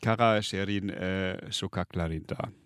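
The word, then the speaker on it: Kara Şehrin Sokaklarında: Ich probiere es jetzt nochmal mit Breck, wie es wirklich ausgesprochen wird. Kara Sherin Shukaklarinda.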